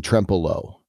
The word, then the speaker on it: Trempealeau: And then I'll do one other shout out because I was just in this place last weekend is Perot State Park in Trempealeau, which am I now pronouncing that correctly? – You've learned.